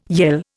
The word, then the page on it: yell: Indian